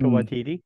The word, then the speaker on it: Waititi: how do you say his name, Waititi? – Taika Waititi, yeah.